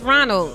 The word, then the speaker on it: Roanoke: Just Roanoke.